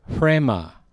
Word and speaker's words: rhema: But logos, two soft O's, and the word rhema, which is R-H-E-M-A, but it's one of those things like... it's a word where the order of the letters is reversed in the pronunciation. So it's almost like the H comes first.